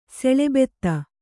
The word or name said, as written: ಸೆಳೆಬೆತ್ತ